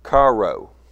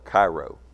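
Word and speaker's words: chairo: Joy is the word charo. It's C-H-A-I-R-O. That's the way it's spelled, Cairo.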